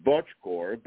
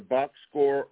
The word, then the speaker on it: Bocskor: It's the correct pronunciation in Transylvania, which is where my family is from, is Bocskor, but in the U.S. it's pronounced either Bocskor or Bocskor.